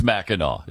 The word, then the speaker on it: Mackinac: It's spelled, why do they spell it Mackinac when it's Mackinac?